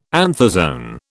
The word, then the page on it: anthozoan: British English